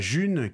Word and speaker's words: Ğ1: Euh, la June, qui s'écrit donc G avec un accent un petit peu bizarre hein, mais qu'on prononce la June, c'est une monnaie électronique, on parle aussi d'une crypto-monnaie.